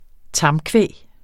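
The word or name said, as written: tamkvæg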